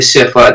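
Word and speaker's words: SFWA: So people who aren't familiar with CIFWA, that stands for the Science Fiction and Fantasy Writers of America. The second F is silent.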